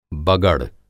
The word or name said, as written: बगड़